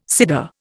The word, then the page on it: cyder: Indian